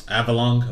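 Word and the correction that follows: Avalugg: whatever? – Avalug.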